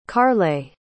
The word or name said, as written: kaarle